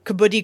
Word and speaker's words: kabaddi: It's K-A-B-A-D-D-I, kabaddi.